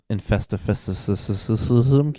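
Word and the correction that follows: Infestissumam: I can't fucking say it. How would you say it? – Infestissimum. – Yeah, what he said came out.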